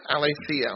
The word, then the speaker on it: aletheia: The Greek word for truth here is aletheia. Aletheia, I'll spell it for you if you'd like. It's A-L-E-T-H-E-I-A.